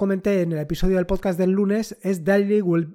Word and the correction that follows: Daily Wallpaper: well uh, madre mía, cómo estoy con la pronunciación de inglés. Daily Wallpaper. ¿Qué pasa con Daily Wallpaper?